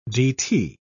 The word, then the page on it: dt: British English